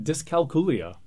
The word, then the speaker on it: dyscalculia: Dyscalculia.